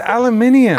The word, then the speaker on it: aluminium: And you said aluminium. – It's aluminium. – Aluminium. That did sound really fancy. And crazy. – I was like, wow, I've been saying that wrong this whole time.